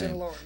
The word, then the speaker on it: Sinaloa: So we reached out to a lot of different folks, and then we came across a band called Los Cuates de la Sinaloa. Jeez, I'm mispronouncing your name. – Sinaloa, yeah.